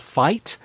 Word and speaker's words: Fite: So the deals for those are still being worked out, but one of the streaming providers that will have it is a company called Fight, and that's spelled F-I-T-E, and they will go ahead and have the games available at that $29.95 price point per game.